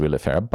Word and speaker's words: Olivier: It's pronounced Willifair, but it's spelled O-L-I-V-I-E-R, and Davi with a W. Season 1, Episode 4, Melissa Clark Reynolds, O-N-Z-M.